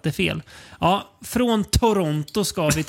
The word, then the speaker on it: Toronto: Man, man, man ska inte uttala T. – Nej, det andra tet. Men jag tänker ändå säga Toronto.